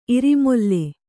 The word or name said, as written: ಇರಿಮೊಲ್ಲೆ